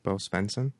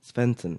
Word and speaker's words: Bo Svenson: Stevenson? No, that's not how you say it, right? – Bo Svenson? – Svenson. Yeah.